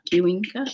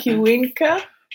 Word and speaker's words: Cuenca: I live in Cuenca, Ecuador. Maybe it's Cuenca. – Cuenca.